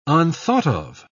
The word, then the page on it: unthought-of: British English